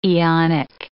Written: aeonic

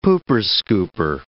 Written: pooper-scooper

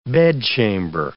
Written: bedchamber

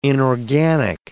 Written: inorganic

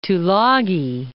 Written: Tulagi

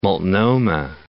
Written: Multnomah Falls

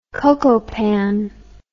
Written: cocopan

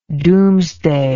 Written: domesday

